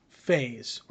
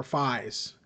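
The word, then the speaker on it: Faiz: So I wanted to watch Kamen Rider Faze or Fize. It's they pronounce either way, depending on who you talk to.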